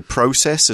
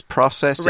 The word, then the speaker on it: process: Now, some people, including myself, I think you can say process as well as process. – Right. – It's one of those with two pronunciations, both okay.